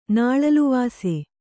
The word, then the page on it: ನಾಳಲುವಾಸೆ: Kannada